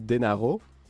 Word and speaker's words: Dan Harrow: Dan Harrow, si on le prononce pas à l'anglaise mais à l'italienne, ça se dit Denaro.